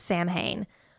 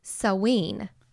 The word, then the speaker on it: Samhain: So we usually call it Samhain, but that's actually not the correct pronunciation. – Oh, here comes a grammar lesson. – It's actually pronounced Saween.